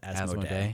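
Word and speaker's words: Asmodée: I checked it. I guess the French pronunciation is Asmodee.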